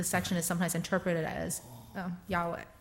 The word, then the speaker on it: Yahweh: So how do we pronounce YHWH? We don't know。 – 那后来人就把它加几个字啊，变成耶和华。 – So people eventually added vowels, and that's how it became Yahweh.